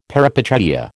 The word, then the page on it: peripeteia: American English